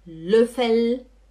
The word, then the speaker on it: Löffel: Por favor, o L é L, L, L, e não U, não é Gabel, não é Löffel, não é Tzetel, é Gabel, Löffel, Tzetel, hum?